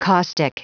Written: caustic

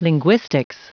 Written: linguistics

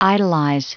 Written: idolize

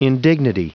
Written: indignity

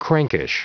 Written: crankish

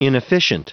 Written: inefficient

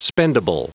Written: spendable

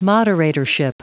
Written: moderatorship

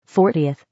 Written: 40th

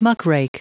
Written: muckrake

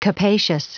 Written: capacious

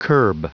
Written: curb

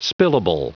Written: spillable

